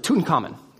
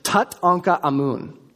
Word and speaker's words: Tutankhamun: See, in English, we pronounce his full name really fast. We pronounce it Tutankhamun, right? That's how we pronounce his name. But the Egyptians would have pronounced it Tutankhamun, which literally translates to the living image of Amun.